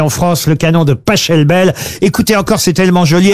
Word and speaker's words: Pachelbel: Ça s'écrit P-A-C-H-E-L-B-E-L. – Mais il adorait l'Italie. Ah, mais par contre, s'il est allemand... – Canelloni, ça se... pas Schelbel. – S'il est allemand, ça se dit Pachelbel.